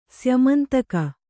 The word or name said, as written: ಸ್ಯಮಂತಕ